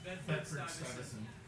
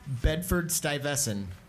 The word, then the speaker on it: Bedford-Stuyvesant: Bedford-Stuyvesant. – One more time? – Bedford-Stuyvesant.